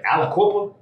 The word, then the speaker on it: Aliquippa: and uh, I hope I'm pronouncing this right. Aliquippa. That's it. The quip, baby.